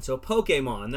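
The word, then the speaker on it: Pokémon: It's poke, and then think about it like this, the letter A, Mon, so Pokemon. That's how you say the word.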